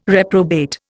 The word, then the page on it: reprobate: Indian